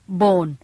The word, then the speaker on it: Bohn: Bone B-O-H-N Bone ซึ่งออกเสียงเหมือนกับ B-O-N-E Bone